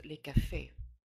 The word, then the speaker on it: les: So when we have the plural, we see that the S is not pronounced, such as les cafés. We do not say les cafés, like you would say, let's say, the dogs in English, or in Spanish, los perros, the dogs.